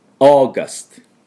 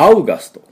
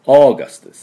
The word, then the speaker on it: August: August。これで完成です。意外と簡単ですね。これはアウガストっていう必要はありません。オーガストです。